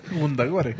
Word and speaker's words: Wundagore: Yo lo pronuncio como Dios manda, o por lo menos como se lee. – Wundagore.